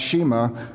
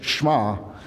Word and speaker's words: Shema: This morning, as I mentioned, just Shema, when I told Nathan what I was going to be preaching on, he said, be sure you say it right. It's not Shema, it's Shema. And that's the Hebrew word for Deuteronomy chapter 6, verses 4 and 5.